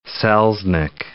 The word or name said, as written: Selznick